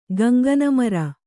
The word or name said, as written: ಗಂಗನಮರ